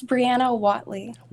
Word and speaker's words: Briana Whatley: Yes, it's Briana Whatley.